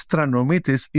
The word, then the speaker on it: Stranomitis: My last name is Stranomitis, is a long Greek name, which makes no difference in my opinion to the industry.